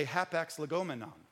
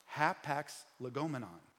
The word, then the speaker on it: hapax legomenon: But a hapax legomenon, which I'm saying that right because I had Google say it to me 15 times. So I am saying it right.